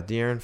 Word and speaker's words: De'Aaron: Yeah, De'Aaron Fox.